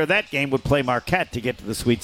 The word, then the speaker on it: Marquette: I have seen, I think ESPN's has Marquette against Colgate, and then if they win, Marquette wins.